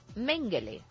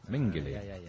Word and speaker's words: Mengele: El otro día Cebrián comentó del tristemente célebre Josef Mengele y se decía Mengele. – Y dice que no. Que se pronuncia Mengele. – Mengele.